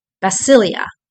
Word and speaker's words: basileia: And kingdom is the word basileia, and it's spelled BASILEIA.